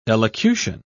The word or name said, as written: elocution